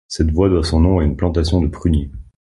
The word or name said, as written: pruniers